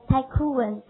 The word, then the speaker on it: tycoon: 发音挺有感觉，tycoon，太酷了，对不对？